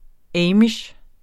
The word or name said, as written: amish